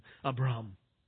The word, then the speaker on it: Abram: Abram, in the Hebrew pronunciation, Abram.